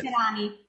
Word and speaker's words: veterani: Felice giorno dei veterinai. – Va bene. – Veterani. Oh, I'm going to be fired here too again. Veterinaria, something else. Felice giorno dei veterani. Let's say it right.